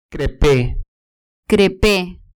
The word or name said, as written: crepe